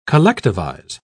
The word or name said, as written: collectivize